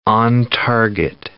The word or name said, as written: on-target